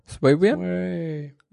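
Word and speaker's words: SwayWM: Ja, SwayVM. VM wie Window Manager und Sway wie Sway.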